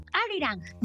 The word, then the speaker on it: Arirang: Arirang.